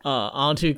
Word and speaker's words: Anhtu: Yeah, just follow me on my Twitter at the handle Antu Comedy. That's spelled A-N-H-T-U, the word comedy.